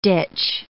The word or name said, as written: ditch